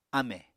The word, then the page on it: ámè: Apatani